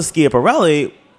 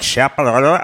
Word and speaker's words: Schiaparelli: Elsa Schiaparelli was the opposite, where it was high voltages of color, prints, really wild.